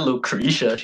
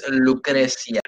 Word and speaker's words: Lucrecia: not Lucretia. She's not American. It's Lucrecia.